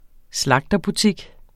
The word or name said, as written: slagterbutik